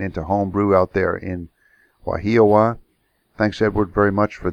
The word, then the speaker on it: Wahiawa: Let me make sure I get it pronounced right. Wahiawaha. No. Wahiawa. That's it.